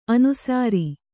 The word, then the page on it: ಅನುಸೌರ: anusaura